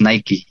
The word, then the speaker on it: Nike: Que muchos todavía dicen Nike, pero creo que la pronunciación correcta es Nike.